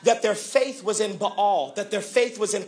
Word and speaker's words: Baal: B A A L. Some people pronounce it Baal. The correct pronunciation is Baal.